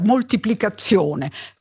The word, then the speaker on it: moltiplicazione: Se noi, tutti noi, quale che sia la nostra provenienza geografica, diciamo, pronunciamo questa parola, pronunciamo una Z intensa, doppia, moltiplicazione, con due Z, ma in realtà la parola va scritta con una sola Z.